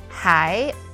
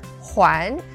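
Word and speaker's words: Hai (还): And today we're going to talk about the character mind map for the word Hai or Huan. So two different pronunciations with different meanings.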